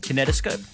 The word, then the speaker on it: kinetoscope: Kinetoscope? Close. That was good. – You can't spell bananas. – Low blow. – How do you say it? – Kinetoscope. – Right.